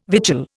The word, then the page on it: vigil: Indian